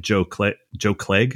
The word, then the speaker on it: Joe Clegg: And then when I see somebody like Joe Clegg, is that how you say it? – Yeah.